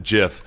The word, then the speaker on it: GIF: Hey, by the way, in terms of the pronunciation of GIF or JIF, one of our listeners pointed out that the guy who invented the GIF does pronounce it JIF.